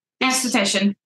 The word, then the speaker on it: esthetician: Our award-winning, I'm going to say this wrong, how do you say it? – Esthetician. – Esthetician with over 30 years experience in corneotherapy and skin science.